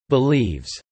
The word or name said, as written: believes